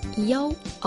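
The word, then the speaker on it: Yao (幺): Outro detalhe interessante é que quando os chineses falam o número Yi, um em algarismo arábico, eles pronunciam Yao ao invés de Yi.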